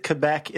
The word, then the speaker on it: Quebec: Is it Quebec? Is it Quebec? Is it something entirely different? Is it like Putin? – It depends on where you're from. I say Quebec.